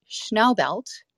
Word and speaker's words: Schnaubelt: So it's Schnaubelt. It's like Schnauzer.